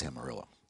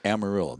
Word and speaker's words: Amarillo: It is Amarillo. – I still feel like it should be Amarillo. – No, it's Amarillo. There used to be a song. – But it's George Strait. From the Hispanic word for yellow. – Yeah. – Which is pronounced Amarillo.